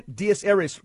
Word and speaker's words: Dies Irae: It's pronounced in Latin once again, Dies Irae, correct? – Yes.